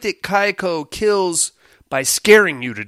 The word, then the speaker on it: Kayako: I doubt any Terror, Kayako. I always fuck up that pronunciation. Correct pronunciation is Kaiko. It's a very quick name. Kaiko. That's how the Japanese do it.